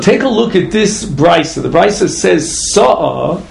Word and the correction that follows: se'ah: or se'ah, actually, if you pronounce it correctly, which is a certain volume, which is the equivalent of six kamin, truma.